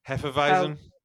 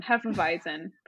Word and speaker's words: Hefeweizen: Hefeweizen. – That one. Hefeweizen.